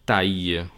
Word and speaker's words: taille: Habíamos visto que la AI se pronuncia normalmente E, excepto en algún caso como este, que tenemos esa combinación de letras AILLE, taille. Es una excepción y no se pronuncia taille.